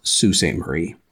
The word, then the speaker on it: Sault Ste. Marie: Marie, S-A-U-L-T, but it is pronounced Sault Ste. Marie. Just wanted to let you know that.